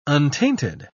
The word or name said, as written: untainted